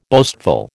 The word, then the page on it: boastful: American English